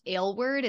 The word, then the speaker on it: Aylward: And my last name is pronounced Aylward. It's kind of a funky one. So just in case you're wondering about that too, like ginger ale word.